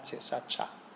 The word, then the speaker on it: shachah: 193 veces se menciona la palabra escrito literalmente sería S-H-A-C-H-A-H, Shacha. Se pronuncia Shacha.